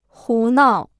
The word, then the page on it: 胡闹: hú nào